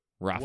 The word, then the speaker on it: Rafe: I have one with Luke, and it's just to say that Luke is played by Rafe Spall. I don't know if I'm pronouncing that correctly, who is Noel from Rafe.